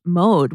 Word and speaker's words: Maude: And they also launched Mode, which is spelled M-A-U-D-E.